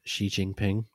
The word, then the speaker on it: Xi Jinping: Looks just like Xi Jinping. Is that his name? That's how you say it, right? – I think so. Yeah, I'm sure you said it perfectly.